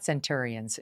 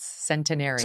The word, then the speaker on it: centenarians: but it's not centurions, it's centenarians.